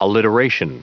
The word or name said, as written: alliteration